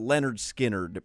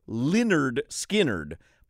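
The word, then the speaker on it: Lynyrd Skynyrd: The beginning of the show, I was talking about Leonard Skinner because I had a conversation with Luis Diaz from Power 96 before our show started.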